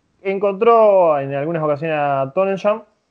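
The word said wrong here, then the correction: Tonyan: que ya no sé si se pronuncia así. Pero Tonian. – Tonian.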